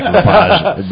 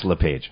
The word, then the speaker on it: LaPage: Now I want to say it like LaPage, but it's LaPage.